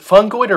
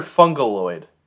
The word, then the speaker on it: fungoid: We should release the is it fungoid or fungaloid? – Fungoid. – I don't know why I added that extra syllable, but...